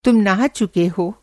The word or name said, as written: नहाना